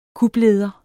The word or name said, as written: kupleder